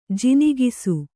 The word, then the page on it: ಜಿನಿಗಿಸು: jinigisu